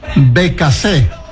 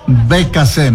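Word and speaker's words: Beccacece: Bacassé, así es la forma de pronunciar, Bacassé, me están corrigiendo.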